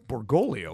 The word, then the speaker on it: Bergoglio: Borrelio? – Bergoglio. – Bergoglio. – You don't even pronounce his name right, which I like.